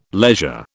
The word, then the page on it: leisure: British English